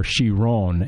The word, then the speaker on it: Chiron: 3,599 parts for this Bugatti Chiron, or Chiron, as it's correctly pronounced.